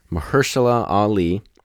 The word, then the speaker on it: Mahershala Ali: All right, and then Supporting Actor, Mahershala Ali,